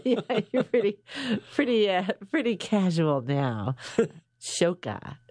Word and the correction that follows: Shaka: is that right? I said it right? Shaka? – Shaka. – Shaka.